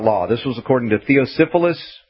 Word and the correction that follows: Theophilus: Did I say that right? No, that sounds like a venereal disease. I didn't mean to say it that way. Theophilus Parsons, or Theophilus Parsons.